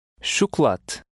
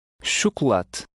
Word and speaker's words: chocolate: Here, both the O's will have the same U sound, and the final letter is almost silent. – Chocolate. Chocolate.